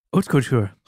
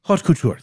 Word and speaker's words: haute couture: Hope I pronounced that right. Haute couture? Whatever. What do you think? Ho? Ha? Couture? Is the T silent? – Haute couture. – Haute couture. Thank you, sir.